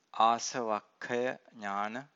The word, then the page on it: asavakkhaya nana: Pali